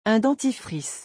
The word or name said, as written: un dentifrice